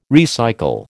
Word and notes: recycle: American English

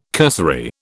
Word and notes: cursory: British English

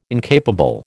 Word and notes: incapable: American English

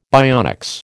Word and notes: bionics: American English

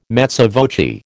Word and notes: mezza voce: American English

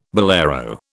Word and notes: bolero: British English

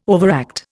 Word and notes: overact: Indian

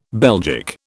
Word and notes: Belgic: British English

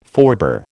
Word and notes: forebear: American English

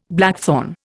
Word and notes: blackthorn: Indian

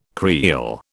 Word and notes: creel: British English

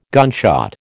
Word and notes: gunshot: American English

